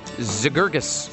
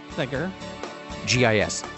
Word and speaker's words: Zegergis: First name is M-E-D-D-A, Meta. – Okay. – Last name, Z-E-G-E-R-G-I-S. Zagurgis. – It's not that hard. – No, you're adding another syllable there. There's no A. – Oh. – It's Z-E-G-E-R, like Zegur. – Zegur. – G-I-S.